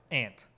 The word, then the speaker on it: aunt: I pronounce like ant. – Ant. – Like a bug. – Yeah. Say like ant.